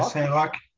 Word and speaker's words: Saint-Roch: R-O-C-H. Is that how you pronounce it? – Yeah, Saint-Roch.